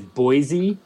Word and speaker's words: Boise: He says Boise incorrectly. He pronounces it with a voiceless S sound.